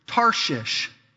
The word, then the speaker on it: Tarshish: Next awkward word is Tarshish.